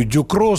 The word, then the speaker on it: Ducrot: А фамилию французскую Дюкро с буквой «Т» на конце, которая не читается, он произносит, вот как я пытаюсь с французским акцентом, у меня, конечно, ни хрена не получается, но он говорит «Дюкро».